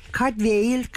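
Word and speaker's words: Cadfael: Aslında bu Katfael de başka bir dert, çünkü çok farklı şekillerde telaffuz ediliyor. Her şeyden önce Katfael Galli, yani İngiliz değil, asıl olarak Galli ve Cadwail, Cadwail, Cadwail gibi şekillerden, ben hani her seferinde yanlış bir şey söylemeyelim, gördüğümüzü okuyalım diye Katfael'i tercih ettim. Siz arzu ediyorsanız kendi kendinize arada Galcesinde söyleyebilirsiniz. Zaten tam olarak doğru telaffuz etmek de biraz zor olacakmış gibi görünüyor.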